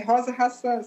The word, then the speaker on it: Rosa Hasan: É Rosa Hassan, sim.